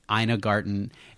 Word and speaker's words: Ina Garten: In a Garden. Ina Garten is is Don't your.